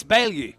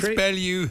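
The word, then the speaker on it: Baillieu: Some people struggle knowing how to pronounce his name, right? So here's a technique. Knock, knock. – Who's there? – Chris Ballylou. – Chris Ballylou who? – Yes, the former rower and chair of sports.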